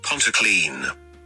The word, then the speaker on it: Pontyclun: Pontyclun. – Yes. Pontyclun. – Is that right? Pontyclun. – Yeah. – But it's spelled Pontyclun, isn't it? – Yeah, but it's Welsh.